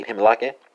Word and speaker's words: glimlachen: So it's going to be Himlake, in Dutch, spelled G-L-I-M-L-A-C-H-E-N, right?